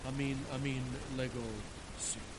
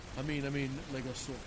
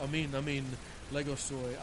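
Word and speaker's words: amin, lego so (ἀμὴν λέγω σοι): When Jesus says, amin, lego si. And I know some of you that know the Greek language say that would understand that I'm mispronouncing it. It should be, amin, lego so.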